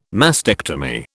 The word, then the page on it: mastectomy: British English